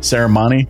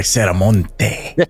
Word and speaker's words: Serramonte: How do you pronounce it? Ceremony? – Yes, sir. – I would have went way more Hispanic. I was going to be like Ceramonte.